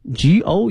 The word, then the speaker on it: gourmet: 这里面这个美食啊我们在前几期也讲过，它叫 gourmet， G-O-U-R-M-E-T，这个词呢它的意思不难哈，美食，但是呢它的发音同学们要注意了，后面那个 T 呢是不发音的，